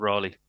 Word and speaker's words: Raleigh: We'd say Raleigh in England, but is it pronounced Raleigh? – It's pronounced Raleigh. – It is in America, yeah.